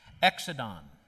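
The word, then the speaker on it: Exodon (ἔξοδον): Now, that word departure in Luke's language is pronounced this way Exodon.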